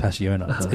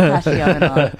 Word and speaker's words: Passiona: Also, I learned from this article that it's not pronounced Passiona. It's Pashona. – No, that's wrong. It should be Passiona.